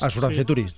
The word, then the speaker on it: Asuranceturix: Asuranceturix.